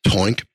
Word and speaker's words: Toink: Yes, it is Toink because it rhymes with oink, because we break our piggy banks to spend with Toink.